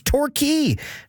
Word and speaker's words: Torquay: And also shout out to 306er Lori, who heard the shout out to Torquay, Saskatchewan, but also advised that I'm pronouncing the English version wrong. It's Torquay.